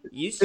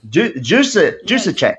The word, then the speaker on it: Juszczyk: Juszczyk. It's the truth. Juszczyk.